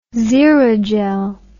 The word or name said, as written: xerogel